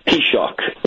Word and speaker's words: Taoiseach: Yeah, if you take the first half of one of your former presidents, Theodore Roosevelt, take the T, and then shock, Taoiseach is the correct pronunciation. Shock.